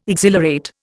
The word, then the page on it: exhilarate: Indian